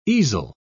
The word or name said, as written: easel